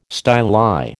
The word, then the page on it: styli: American English